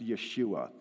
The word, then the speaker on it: Yeshua: That's Jesus' name, or in Hebrew, it's pronounced Yeshua.